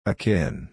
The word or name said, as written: akin